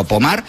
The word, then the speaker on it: Pomard: Se escribe Pomard, como si fuera en castellano, pero Pomard, pues allí en Francia.